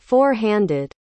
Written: four-handed

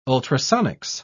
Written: ultrasonics

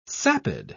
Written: sapid

